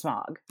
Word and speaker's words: Smaug: All of us were so upset about Smaug. – Yes. – Smaug. Nobody would ever pronounce it that way. It's Smaug.